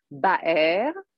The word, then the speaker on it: Baer: Well, I was born in France, so it's a German name, but you can spell it Baer. And you can, in Israel, adopt the Bet Aleph Resh writing, which makes it Be'er, like a well.